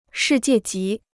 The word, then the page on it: 世界级: shì jiè jí